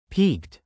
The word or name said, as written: peaked